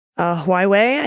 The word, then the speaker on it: Huawei: Am I saying that right? – Huawei, I think.